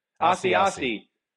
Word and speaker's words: Asiasi: Asiasi. – Don't be shocked if we're sitting here 2 or 3 years from now with Asiasi being in the conversation for the best tight end in football.